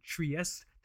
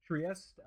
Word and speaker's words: Trieste: Details on how to enter and win the Notice watches Trieste. That's not how you say it. Trieste. It's an Italian word, you said, right? – Yes. – Yeah, I don't know why I said like a French word.